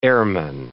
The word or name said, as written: airman